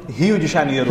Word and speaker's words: Rio de Janeiro: How do you say it properly? – Rio de Janeiro. – Rio, because the R is with the H.